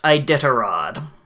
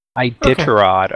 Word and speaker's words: Iditarod: I think the next story is Rofaxans, and the word is pronounced Iditarod. – Iditarod, okay.